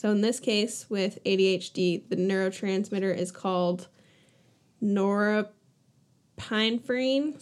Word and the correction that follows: norepinephrine: saying it wrong probably, but it's also the first. – Norepinephrine? – God damn it. Yes.